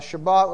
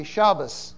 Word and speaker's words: Shabbat: Ashkenazis don't pronounce a T at the end of words. They pronounce it as an S. So Shabbat would be Shabbos. Good Shabbos. Whereas Sephardim say Shabbat. Middle Eastern and Spanish Jews say Shabbat. And Eastern European Jews say Shabbos, and Latino Jews say Shabbat, or Middle Eastern Jews say Shabbat.